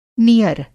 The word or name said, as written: नियर